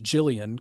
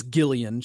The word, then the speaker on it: Gillian: I pronounced it Jillian because you can also say it that way. That is, it is Gillian. She pronounces it with the hard G.